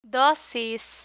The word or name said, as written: dosis